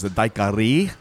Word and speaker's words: daiquiri: his daiquiri, which is the actual correct pronunciation. I don't know where daiquiri came from.